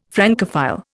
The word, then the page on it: Francophile: Indian